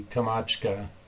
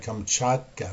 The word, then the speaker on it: Kamchatka: And so you can see, for example, the Kamchatka, I'm sorry, Kamchatka, I have to say it correctly, Kamchatka Peninsula mountain range.